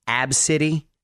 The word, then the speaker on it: Abcde: The name of this five-year-old little girl, by the way, is Abcity, but it's spelled A-B-C-D-E.